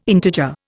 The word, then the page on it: integer: Indian